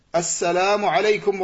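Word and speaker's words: assalamu alaykum (السلام عليكم): Donc la prononciation correcte de salam, c'est de dire assalamu alaykum wa rahmatullah.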